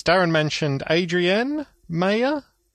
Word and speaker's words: Mayor: I think it's Meyer. – M-A-Y-O-R. – Yeah, as in Mayor. Meyer.